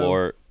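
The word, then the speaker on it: Malört: There's an umlaut for the O. It's Malort. – Malort. – Malort. – Malort. M-A-L-O-R-T with an accent over something.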